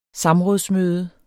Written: samrådsmøde

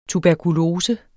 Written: tuberkulose

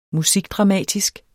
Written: musikdramatisk

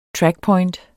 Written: trackpoint

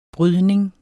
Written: brydning

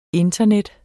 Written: internet

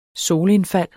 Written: solindfald

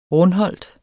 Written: RUNDHOLT

